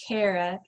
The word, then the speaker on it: Kara: Actually, my name is pronounced Kara, like I care a lot.